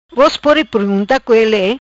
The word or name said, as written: Co Vos Pode